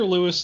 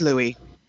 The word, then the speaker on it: Louis: The future Louis XIII came to Versailles for his first hunting trip in 1607. – It's Louis.